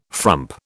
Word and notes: frump: British English

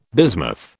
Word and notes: bismuth: British English

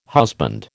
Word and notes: husband: American English

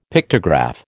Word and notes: Pictograph: American English

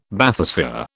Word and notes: Bathysphere: British English

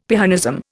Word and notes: Pyrrhonism: Indian

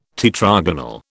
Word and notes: tetragonal: British English

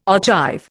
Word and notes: ogive: Indian

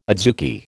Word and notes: adzuki: American English

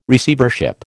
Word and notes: Receivership: American English

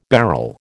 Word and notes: beryl: American English

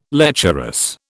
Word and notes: lecherous: British English

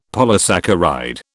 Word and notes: polysaccharide: British English

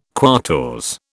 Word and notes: quatorze: British English

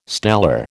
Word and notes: stellar: American English

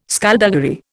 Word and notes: skulduggery: Indian

